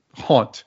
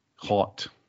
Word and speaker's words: honte: I believe it's haunt. It is spelled, I believe, like H-O-N-T-E. Haunt.